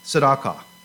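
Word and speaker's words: tzedakah (צדקה): It's pronounced tzedakah.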